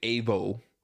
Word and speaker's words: Evo: I was pronouncing it Evo Morales. I believe is how it's supposed to be pronounced.